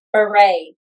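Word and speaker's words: Bure: Okay, and then the women were Nicki Minaj and Natasha Burr. – Beret. – Oh, yeah, I forgot. We literally just went through this, and I still said it wrong.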